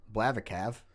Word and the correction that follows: Blaviken: Blavikov? – Blaviken. – Blaviken.